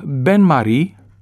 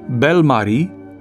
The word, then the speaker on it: bain-marie: Unii vorbitori, care au preluat termenul după ureche, pronunță bain-marie, ceea ce înseamnă altceva, înseamnă frumoasă Maria, alții îl pronunță bain-marie, alții bain-marie, care nu înseamnă nimic, ci este doar un rezultat al ignoranței.